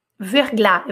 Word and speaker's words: verglas: Verglas. Não, não, não tem E, não, não falo S.